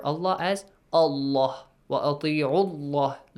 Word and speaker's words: الله: So the letter lam in the word Allah is pronounced heavily since you have a dhamma before.